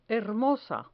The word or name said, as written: hermosa